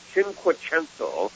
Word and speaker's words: cinquecento: The word of the day is cinquecento.